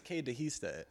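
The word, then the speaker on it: Qué dijiste: I think it's Cade Dehiste. – There you go. Thank you very much.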